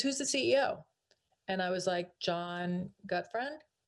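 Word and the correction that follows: Gutfreund: And I said it because I read it through the annual report. He goes, it's John Goodfriend. And he said, that's how you pronounce it.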